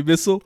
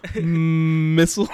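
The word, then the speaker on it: Mistle: Missile. Missile. – It's because it's spelled like thistle, like, not like missile.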